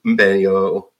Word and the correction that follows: Mbeumo: I can't pronounce that name. – Mbeumo, yeah.